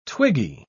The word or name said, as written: twiggy